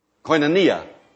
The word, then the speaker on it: koinonia: I don't know if the word is pronounced koinonia or koinonia. Koinonia.